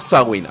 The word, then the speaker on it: Samhain: Viene la celebración celta de el Samhain Muchos han de conocer en realidad que se escribe como Sama con H, pero después de ver demasiados videos de cómo se pronuncia eso en irlandés real, se fonetiza como Sawin, así que por favor no me lo reclamen.